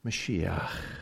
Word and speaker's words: Mashiach: The last word, it's a chet, has to be pronounced in the very back of your throat. So it's a Mashiach. Mashiach.